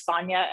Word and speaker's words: Sonya: And then my name is Sonya, SONYA, last name ROEMISCH.